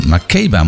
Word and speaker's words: Makeba: Makeba moet ik zeggen trouwens. En misschien spreek je het nogal weer anders uit.